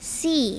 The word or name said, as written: see